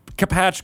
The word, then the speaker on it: Capatch: Did I pronounce Capatch correctly? – You did.